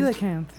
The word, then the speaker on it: coelacanth: Yeah, it's called coelacanth. Yeah, is how it's pronounced.